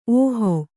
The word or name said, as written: ಓಹಿ